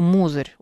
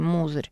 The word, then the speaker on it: Мозырь: Так, мне тут, значит, пишут, как правильно пишется, как правильно надо читать Мозырь. Хорошо, Мозырь. Окей, Мозырь.